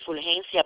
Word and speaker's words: Fulgencia: You know, my first name is Fulgencia, but I'll spell it for you, if that's okay.